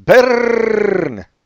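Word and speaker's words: Byrne: So his last name really should be pronounced Bern.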